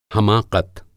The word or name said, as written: हमाक़त